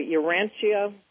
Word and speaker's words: Urantia: I hope I still didn't mispronounce that book, the Urantia.